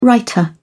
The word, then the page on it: writer: British English